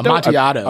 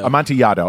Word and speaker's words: Amontillado: But in there, they did a sort of graphic adaptation of, I never know if I'm pronouncing the word right, but The Cask of Amontillado? Amontillado? – Amontillado. – Amontillado. Okay.